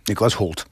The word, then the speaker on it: Nicholas Hoult: No tässä siis Tolkienia esittää Nicholas Hoult, miten se lausutaan? – Nicholas Hoult.